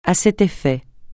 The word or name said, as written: à cet effet